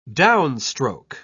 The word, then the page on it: downstroke: British English